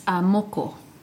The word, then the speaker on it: moko: It's moko.